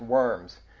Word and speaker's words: Worms: It's actually pronounced Worms, but it's V-W-O-R-M-S-E or something like that, how it's pronounced and spelled.